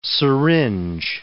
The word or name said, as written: syringe